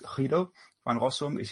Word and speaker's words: Guido van Rossum: Guido van Rossum, or probably if you pronounce it Dutch, is Guido van Rossum.